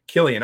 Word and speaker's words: Cillian: Letty Lou says, Killian. I figured Letty would know. She's Irish, you know. – Yeah. – So. But we've mispronounced it for so many years that it's going to be really like I had Nat on last night.